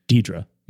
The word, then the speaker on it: Deirdre: Is Jamie Lee Curtis's character's name is pronounced Deirdre. – Yep. – Deirdre.